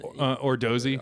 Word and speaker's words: Odorizzi: The Odorizzi, is that how you pronounce it? – Ordozy.